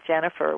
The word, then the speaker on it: Jennifer: So you probably know how to spell Jennifer with two N's, and my last name, Schneider, is spelled Schneider.